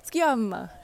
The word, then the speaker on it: Sciamma: sorry, I mispronounced her name earlier in the letters, like, Sciamma, she's like an Italian,